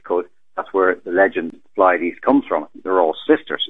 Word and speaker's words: Pleiades: So I pronounce it Pleiades, or if you don't like that, just say Seven Sister Star Cluster,